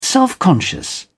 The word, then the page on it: self-conscious: British English